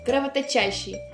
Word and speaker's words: кровоточащий: А знаешь, как просто предложила Людмила Васильевна запомнить ударение в трудном слове «кровоточащий»? Она сказала, что в слове 2 корня. Чтобы никого из них не обижать, ударение решила жить в суффиксе.